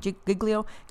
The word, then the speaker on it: Giglio: I think it's Louis Giglio, is how you say his last name, Giglio.